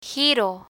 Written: giro

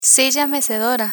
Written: silla mecedora

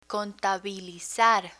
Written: contabilizar

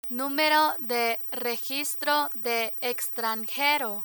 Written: numero de registro de extranjero

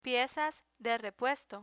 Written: piezas de repuesto

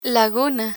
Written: laguna

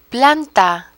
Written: planta

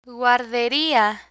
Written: guarderia

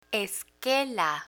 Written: esquela

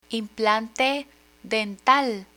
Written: implante dental

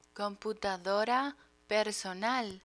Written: computadora personal

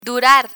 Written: durar

